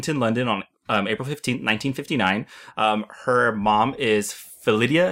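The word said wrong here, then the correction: Phyllida: Is that how you say it? – I think it's Philida. – Philida.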